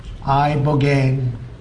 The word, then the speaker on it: Ibogaine: Ibogaine. Remember the name. Is that pronounced relatively properly, would yes.